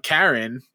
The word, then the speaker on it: Charon: So that happened to me, not with one of the gods, but Charon or Charon, however you say that name. – No, you said it right the first time.